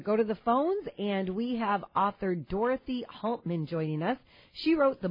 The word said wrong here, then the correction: Halterman: And did I say your last name right? – Halterman. – Halterman, thank you so much.